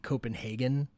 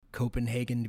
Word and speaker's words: Copenhagen: like Copenhagen to be said, not Copenhagen. Hagen. Oh, okay. Yes. That's actually the Nazi way of saying. Oh. I know, isn't that terrible? Oh, yeah, that's that's the Nazi pronunciation or they like Copenhagen, not Copenhagen.